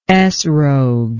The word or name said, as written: etrog